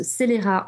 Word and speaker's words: Celera: Alors Celera, ça vient de célérité, c'est en rapport avec la vitesse, mais ça se prononce Celera en anglais comme en français, mais évidemment, l'ironie en échappe au premier.